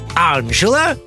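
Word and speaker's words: Angela: Ну, на итальянском, например, Анжела и Анджело. С ударением на первую букву «А».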